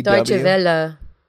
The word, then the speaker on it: Deutsche Welle: Hey, you know that there's a YouTube channel called Deutsche Well or something? – DW? Deutsche Welle.